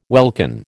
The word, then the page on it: welkin: American English